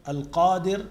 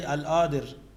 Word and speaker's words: Al-Qadir: Ar-Razzaq, Al-Qadir, do not say Al-Adir.